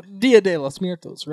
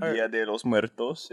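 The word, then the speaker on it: Día de los Muertos: You know, Dia de los Muertos, right? – You said it wrong, but yeah. All right, but Dia de los Muertos.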